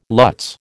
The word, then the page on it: lutz: American English